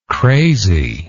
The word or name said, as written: craziness